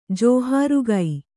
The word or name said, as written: ಜೋಹಾರುಗೈ